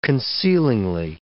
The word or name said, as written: concealingly